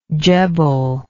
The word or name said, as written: djebel